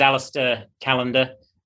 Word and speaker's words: Alastair Callender: My name's Alastair Callender.